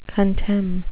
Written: contemn

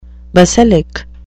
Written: basilic

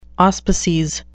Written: auspices

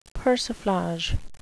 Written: persiflage

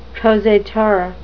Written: prosateur

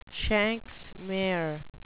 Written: shanks mare

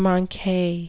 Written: manque

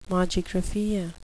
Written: mogigraphia

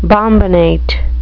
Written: bombinate

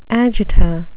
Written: agita

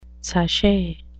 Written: sashay

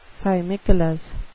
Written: fimicolous

